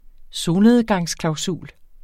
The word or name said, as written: solnedgangsklausul